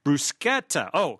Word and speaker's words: bruschetta: It's pronounced bruschetta." She gave us the right pronunciation. – Oh, bruschetta. – Bruschetta. – Bruschetta. But you've got to do it bruschetta. – The CH is pronounced as a K.